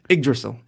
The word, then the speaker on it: Yggdrasil: Yggdrasil. That's it. Ig, not Yig.